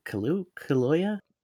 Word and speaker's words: Kaluuya: It's Daniel Kaluuya.